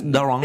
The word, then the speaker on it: Daron: We've been saying it wrong this whole time. – Daron. – It's Daron. – Daron. – Excuse me, Daron. – Okay, with the accent.